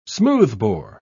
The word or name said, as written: smoothbore